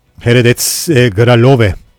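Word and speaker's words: Hradec Králové: Heredet Gralove Çekçe nasıl telaffuz ediliyor ona baktım. Gelmeden önce genelde bakmaya çalışıyorum. Kendi dillerinde neler oluyor, nasıl telaffuz ediliyor onu söylemeye çalıştım.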